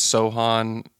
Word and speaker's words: Sochan: I think it's Sochan uh, is how you really pronounce it.